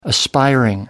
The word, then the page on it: aspiring: American English